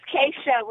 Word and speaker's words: Keisha: It's Keisha with the K, yes.